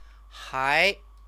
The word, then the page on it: hai: Chinese